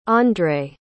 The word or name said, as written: andre